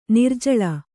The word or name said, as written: ನಿರ್ಜಳ